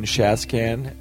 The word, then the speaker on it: Shaskan: Hi, this is Stephen Shaskan, and my last name rhymes with trash can. It's an easy way to remember how to say it.